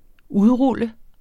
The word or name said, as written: udrul